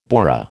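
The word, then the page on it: bourrée: American English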